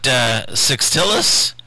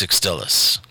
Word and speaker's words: Sextilis: Now, I may be putting the wrong emphasis on the wrong syllable, but it looks to me like it's Sextilis.